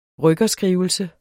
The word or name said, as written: rykkerskrivelse